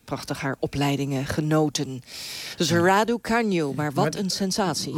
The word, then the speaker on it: Raducanu: De 19-jarige Leila Fernandez en de 18-jarige Emma Raducanu. Zeg ik het goed, Marcelle Mesker? Goedenavond. – Goedenavond. Ja, in de Groot-Brittannië zeggen ze rather can you.